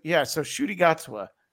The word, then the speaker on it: Ncuti Gatwa: So, do you say his name Is it Ncuti? Is that really how you say it? So Ncuti Gatwa. Yeah.